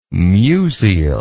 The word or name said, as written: musial